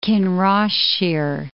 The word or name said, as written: Kinross-shire